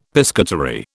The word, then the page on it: piscatory: British English